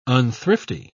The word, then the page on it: unthrifty: British English